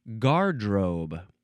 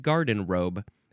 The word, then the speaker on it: garderobe: Now, technically, the pronunciation says that this is supposed to be pronounced gardrobe. Gardrobe? Okay. Gardrobe. Because I said it wrong. I think I said garden robe. It's not garden robe. G-A-R-D-E-R-O-B-E. Gardrobe. Noun from the 15th century.